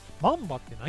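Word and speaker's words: 万葉: 緑の葉っぱ、はい、葉っぱなんですが、マンバってなんやねんって。でして、なんだと思います、うん、これ香川県の方言なんですよ。 – 緑の葉っぱと書いてマンバ。 – いや違います、 1 万の万に葉っぱと書いてマンバ。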